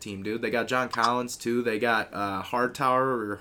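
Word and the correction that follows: Huerter: Herder, yeah.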